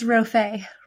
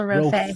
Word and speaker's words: Rofé: It's Rofé, Rachel Rofé. – Rofé, because I just thought, is it pronounced like the posh, or is it pronounced like it looks?